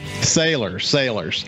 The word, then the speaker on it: Salers: Am I pronouncing your last name correctly? – Sailors.